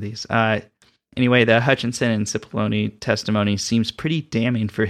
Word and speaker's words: Cipollone: Cipollone? – You got it. – That's it? – Yes, Cipollone.